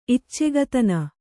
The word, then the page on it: ಇಚ್ಚೆಗತನ: iccegatana